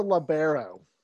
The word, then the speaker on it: libero: What's a libero? – At least you said it right.